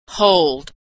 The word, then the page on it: hold: American English